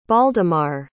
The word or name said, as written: baldemar